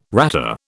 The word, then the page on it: ratter: British English